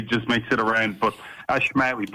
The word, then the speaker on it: Ashmawy: It's, it's, it's, it's, it's actually very phonetic. It's Ashmawy. Okay, Ashmawy.